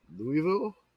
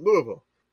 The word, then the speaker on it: Louisville: You're putting too many syllables in Louisville. – Louisville. – Take your tongue, glue it to the bottom of your mouth. Louisville. – It's like I'm saying it wrong. Like everybody says New Orleans wrong. – If it doesn't sound like you're gargling marbles, you're doing it wrong.